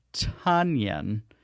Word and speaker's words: Tonyan: He is Robert Tanyan, rhyming with Paul Banyan.